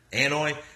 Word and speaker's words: Anoa'i: You know, you hear from Joe Anoa'i, or I'm maybe pronouncing that incorrectly. – Anoa'i.